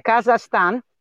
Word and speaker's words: Kazakhstan: oh yeah that's a hard one to pronounce kazakhstan, kazakhstan.